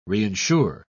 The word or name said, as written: reinsure